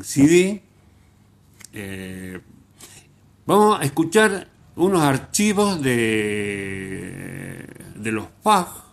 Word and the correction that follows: Fugs: Fug, se escribe F-U-G-S.